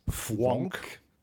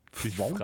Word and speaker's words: Fvonk: Und die Sue Imhoff macht gemeinsam mit der Susanne Höllier eine Sendereihe, die heißt Fwonk. – Fwonk dich frei. – Dich frei. Und Fwonk schreibt man F-V-O-N-K.